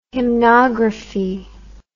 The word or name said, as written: hymnography